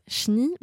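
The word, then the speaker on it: cheni: Alors, précision, ça se prononce chni, mais ça s'écrit chenille avec un E, C-H-E-N-I.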